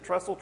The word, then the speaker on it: Tressel: Tressel, okay.